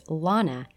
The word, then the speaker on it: Lana: Quote, "Her name was Lana, as in la-di-da," she would say while correcting people. "It offended her ears to hear it pronounced any other way, and it's still jarring for me to hear it pronounced with a flat A, as in land," unquote.